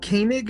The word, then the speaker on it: Koenig: My wife and I just got married at the end of August, and it's Koenig is how it's said, but it's nothing like how it's spelled.